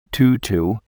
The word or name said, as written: tutu